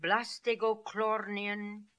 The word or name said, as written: Blastegoklornian